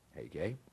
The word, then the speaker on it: Hege: Hege is how you pronounce this guy's name, Hege, the king's eunuch, custodian of the women.